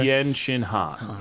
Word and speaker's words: Tien Shin Han: Yeah, Tien, Tien Shen, is that how they say it here? – Or? Tien Shin Han. – Tien Shin Han. Gotcha.